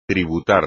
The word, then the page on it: tributar: espagnol